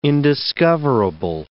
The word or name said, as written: indiscoverable